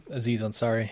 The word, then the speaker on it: Aziz Ansari: 7 Days of Hell, Master of None, the Netflix series, the Aziz and Azari. Is that how you pronounce his name? – Aziz Ansari, yeah.